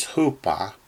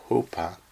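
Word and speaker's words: hupa (ὑπό): The Greek word there for under is hupa. In English, we would spell it H-U-P-O, hupa.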